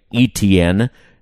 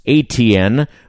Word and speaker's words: Etienne: Travis is too polite to correct those who say ETN, as I was saying last week, but he says it's ATN, or in his native Louisiana, HN.